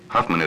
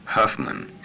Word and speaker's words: Huffman: And I'll say, you know, Rick Huffman, and I say it Southern so that they can understand Huffman, you know, and they say, would you spell it? I said, look, it's Southern for Hoffman, you know, put a U in there instead of an O.